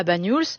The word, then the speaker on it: Bagnols: Comment on le prononce? – Bagnols. Oui, c'est ça.